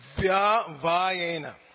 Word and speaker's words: vyavayena: So that first word on the second line, it's vya vayena. Everybody say vya vayena, vya vayena. So please try to get that. The tendency is to compact that and it doesn't come out right.